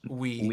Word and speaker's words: oui: B-U-T-T-E-R. W-E-T-C-H. And then O-U-I. – Better Watch... – Better Watch We. – We? – Yeah, because it's OUI, so it's said like we. – Butter wet age we.